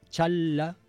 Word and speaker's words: Chang'e: S'escriu com Chang'e 6, però es pronuncia com Challa 6.